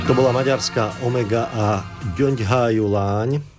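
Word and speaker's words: Gyöngyhajú lány: Ale áno, akurát ten akcent sa nie háju, ale háju, zdá, Gyöngyhájuláň.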